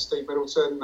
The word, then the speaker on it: Steve Middleton: My name is Steve Middleton.